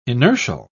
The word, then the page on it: inertial: British English